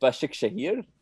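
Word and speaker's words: Başakşehir: You mispronounced it totally. Can you do that again? – But Başak Şehir. That's perfect.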